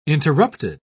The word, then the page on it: interrupted: British English